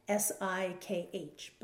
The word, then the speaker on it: Sikh: I'd always thought of it as the Sikh religion, S I K H, but it's actually pronounced Sikh, which is interesting for us English speakers because then we say, oh, well, Valerie is a sick woman, and we know sick to mean someone's ill, but she is not ill.